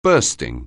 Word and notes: bursting: anglais

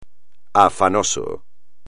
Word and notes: afanoso: espagnol

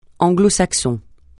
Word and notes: anglo-saxon: francais

